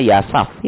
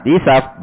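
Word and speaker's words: yisaf (يساف): Sehingga bacanya yisaf. Nah, ya. Ini kalau kita nggak lihat langsung ke kamusnya atau ke tarajim ruwat, bacanya salah kita mesti. Anda lihat banyak di nuskah-nuskah itu tulisannya yasaf ya.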